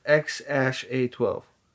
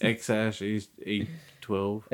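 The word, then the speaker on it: X Æ A-12: I, I don't, uh, I, I can't say I'm like a big fan of either way of the name is pronounced X Ash A twelve. – Yeah, that's how it's pronounced. X Ash A twelve.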